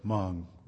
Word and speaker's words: Hmong: By the way, some of you may have seen this word Hmong written out before. It's H-M-O-N-G, but the H is silent.